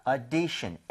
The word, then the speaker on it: audition: 그래서 이거 발음하실 때도요 오디션이 아니라 5에서 조금만 더 벌려주시면 돼요. 그래서 audition, audition 조금만 벌려서 5보다는 차라리 어에 더 가까운 게 낫습니다.